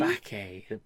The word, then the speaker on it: Bacchae: But I think the one you're sort of referring to, and I know I'm going to pronounce this wrong, is it the Bachi? Or the Bacchae? – The Bacchae.